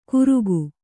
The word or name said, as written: ಘುರುಕು